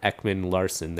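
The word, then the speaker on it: Ekman-Larsson: And I know that when Oliver Ekman Larson, there, I said it right that time. – There you go.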